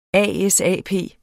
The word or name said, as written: ASAP